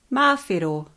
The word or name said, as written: mawhero